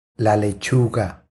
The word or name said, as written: La lechuga